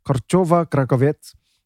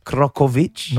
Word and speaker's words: Korczowa-Krakovets: How do you say it again? – Yeah, one more time. – Krakowicz? – No, Korchova-Krakowiec. Although I'm probably reading it wrong because I'm reading it with a slight Russian accent.